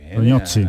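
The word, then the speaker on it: Tognozzi: A ver si tiene tiempo de rectificar a Mateo Tognosi. ¿Ya le has preguntado cómo se dice correcto o no? Tognosi. Con ñ, la G y la N, ñ y las dos Z como la pizza, Toñocci. Toñozzi.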